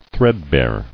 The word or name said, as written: Threadbare